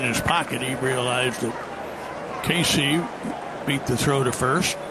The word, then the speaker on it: Caissie: At the bat, this is Owen Casey, C-A-I-S-S-I-E, pronounced Casey.